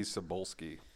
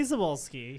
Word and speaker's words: Cebulski: Can you pronounce this guy's name for me? – CB Cebulski. – Oh boy. – I know, white names are really hard to pronounce. – Cheska Sebulba. Sorry.